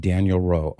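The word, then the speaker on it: Daniel-Rops: But it's pronounced Daniel Rowe, Henri Daniel Rowe.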